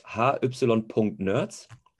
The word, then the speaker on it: hy.nerds: Es ist nicht Hygiene-Nerds, es ist hy.nerds. Ist einfach witzig, finden wir ein bisschen cooler an High-Nerds. Ähm, und ist die, ist die Abkürzung für Hygiene-Nerds. – Ah ja, da habe ich es ja doch richtig gesagt.